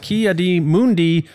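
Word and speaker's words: Ki-Adi-Mundi: Ki Adi Mundi. – Yeah, no, it's... – You didn't write it right, but you said it right.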